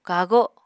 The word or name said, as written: kago